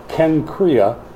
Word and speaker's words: Cenchrea: And at Cenchrea, C-E-N, pronounced like a K, Cenchrea, Paul had cut his hair because he was under a vow.